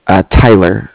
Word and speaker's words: Tyler: Tyler. That's T-Y-L-E-R.